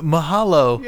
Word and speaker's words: mahalo: Mihalo, Shaitan. – Speaking Hawaiian. – That's, yeah, mahalo.